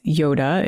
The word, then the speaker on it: Yodha: Um, and the word is Yoda. It's spelled Y O D H A, but it's pronounced the same.